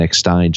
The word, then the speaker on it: Eckstein: I'm saying Eckstein and Eckstein just to make sure that I get both possible pronunciations. – It's Eckstein.